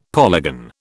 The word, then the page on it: polygon: British English